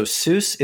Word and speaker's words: SUWS: So Seuss is, it's spelled S-U-W-S.